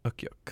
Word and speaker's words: Ukiuq: Ukiuk? – I think so. – U-K-I-U-Q. Ukiuk. – I think that's right.